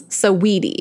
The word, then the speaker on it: Saweetie: It's three syllables. Saweetie.